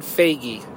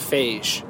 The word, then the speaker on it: Feige: It's Kevin Feige, not Feige. He answered in a Reddit AMA that his name is pronounced Feige.